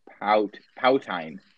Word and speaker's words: poutine: How could you not know how to pronounce that? – Well, poutine. Poutine. English makes no sense. – That is correct.